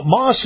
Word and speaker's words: Maus: Now, Moss is spelled M-A-U-S, Nissan.